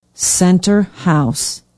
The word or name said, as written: center house